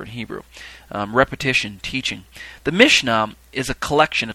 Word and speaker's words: Mishnah: The next term on my list is the Mishnah, spelled M-I-S-H-N-A-H, but it's also spelled without the H on the end at times, Mishnah, or sometimes it's spelled with an E, Mishneh.